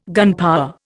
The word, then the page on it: gunpower: Indian